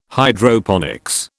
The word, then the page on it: Hydroponics: British English